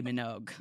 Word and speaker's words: Minogue: Her last name rhymes with Vogue. – Kylie Minogue.